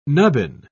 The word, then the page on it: nubbin: British English